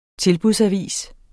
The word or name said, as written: tilbudsavis